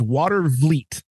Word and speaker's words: Watervliet: But what have we learned about people in Michigan? They butcher their French. It's Watervliet.